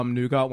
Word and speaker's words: nougat: Made a nougat one, and it like riffs on the Toblerone. – Love that you pronounce the T in nougat.